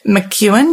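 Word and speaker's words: McKeown: So I've got a bit of a cool quote here, and it is by a guy called Greg McEwen. Did I say that right? – I think so. – It's not spelt that way, but that's how you say it.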